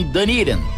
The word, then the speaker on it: Dunedin: Para facilitar a sua busca, se escreve Dunedin, Dunedin, mas os americanos falam Dunedin.